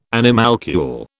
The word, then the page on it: animalcule: British English